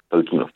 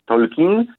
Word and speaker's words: Tolkien: Oczywiście w języku polskim możemy je wymawiać Tolkien, tak jak e, potocznie się mówi, ale jak ktoś jest takim większym nerdem, może mówić Tolkien, tak jak mówiła to rodzina Tolkienów. Zresztą profesor Tolkien akcentował prawdopodobnie nawet na ostatnią sylabę Tolkien.